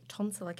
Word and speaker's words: tonsillar: When at age 48, you were diagnosed with tonsillar cancer. Did I say that correctly? – Correct. Rhymes with consular.